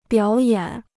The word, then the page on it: 表演: biǎo yǎn